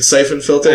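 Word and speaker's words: Syphon Filter: Is it Siphon Filter? – I'm pretty sure everybody says Siphon Filter. – Isn't it S-Y? – It is S-Y.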